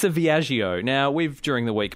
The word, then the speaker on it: Viaggio: Okay, so this is Fiat's new small car has been unveiled, and it's called the Fiat Viaggio.